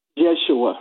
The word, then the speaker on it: Yeshua: Yeshua was the name of, was his name, how it would be pronounced in Hebrew.